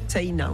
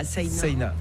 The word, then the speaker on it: Saïna: Ça s'écrit un peu comme la Seine, mais... – Voilà, avec un A à la fin, Saïna, on prononce le I aussi. – Comme si on avait un tréma au-dessus.